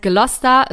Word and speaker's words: Gloucester: Also sie heißt Gloucester, wird aber eher Gloucester geschrieben, also nicht wundern an dieser Stelle.